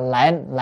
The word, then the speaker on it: lệnh: Chữ này cũng còn âm khác là linh. Ở đây phải đọc là lệnh. Lệnh có nghĩa là mệnh lệnh, là lời giáo huấn. Còn nếu đọc là linh là khiến cho.